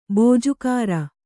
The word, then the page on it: ಬಿಜಾಗರಿ: bijāgari